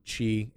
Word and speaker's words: Chi: Then the Kingdom Hearts X, or Chi is the official pronunciation of that new letter, whatever, if for this title, but Union Cross, we'll call it that.